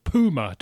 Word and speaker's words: puma: Yeah, where they call them, and they call them puma, don't they? As if it's spelled P-O-O-M-A.